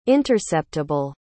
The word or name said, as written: interceptable